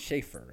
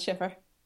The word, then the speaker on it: Schiffer: It's Schaefer, right? – No, it's Schiffer.